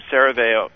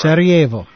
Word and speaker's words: Sarajevo: So this is for Zorn, October 26, 1956, about 3 a.m., Sarajevo. Sorry, I'm probably not saying that right. – Sarajevo.